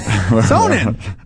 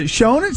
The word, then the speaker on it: Sonnen: I don't know who Sonnen was, but Sonnen. What is it? Shonen? – Sonnen. – Sonnen. Okay, I'll give you that.